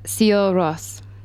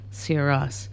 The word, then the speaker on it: Sigur Rós: Sigur Rós. – Sigur Rós. Spelled S-I-G. – S-I-G-U-R-O-S.